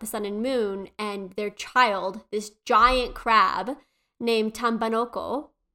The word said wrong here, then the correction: Tambanokano: Sorry. This giant crab named Tam how do I say this? Tambanokano.